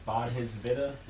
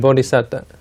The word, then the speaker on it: Bodhisattva: Yeah, I believe it's pronounced the Bodhisattva, the one who attains Nibbana but voluntarily chooses to... – Bodhisattva.